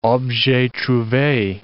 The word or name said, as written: objets trouves